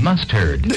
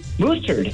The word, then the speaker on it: mustard: Because what you're calling moose, a moose-turd, is mustard. – Mustard. – So, do me a favor. Pronounce mustard how you say it for me again. – Moose-turd.